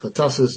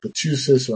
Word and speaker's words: pertussis: Similarly, pertussis is pronounced pertussis and not pertussis or anything like that because of the double S.